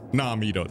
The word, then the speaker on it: Namido: Namido, that's right.